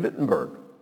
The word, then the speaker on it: Wittenberg: We say Wittenberg because we pronounce W's like a W. Germans do things like drive Volkswagens. But it's their language, so they have a right to say how it's supposed to be pronounced.